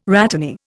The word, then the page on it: rhatany: Indian